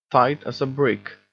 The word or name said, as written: tight as a brick